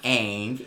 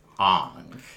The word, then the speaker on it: Aang: His name is not Aang, it's Ong.